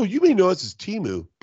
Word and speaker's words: Temu: It's pronounced Temu.